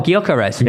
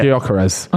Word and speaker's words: Gyökeres: I don't know how to pronounce him properly, but Gyökeres. – Oh, Gyökeres. – Yeah. Gyökeres.